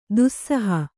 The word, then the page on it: ದಾಸೋಹ: Kannada